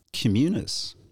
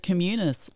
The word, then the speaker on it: Communis: Communis. And the best part about it is everyone has to stop and think about it, how they pronounce our business name. – But it's Communis, like community.